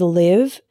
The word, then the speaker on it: live: Another example is live and live. Live with the same I as in sit means to reside.